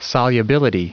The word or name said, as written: solubility